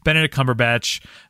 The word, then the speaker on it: Benedict Cumberbatch: Benedict Cumberbatch. – Benny Cumberbatchy. – I thought I said it wrong, but it's not wrong.